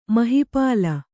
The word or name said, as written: ಮಹಿಪಾಲ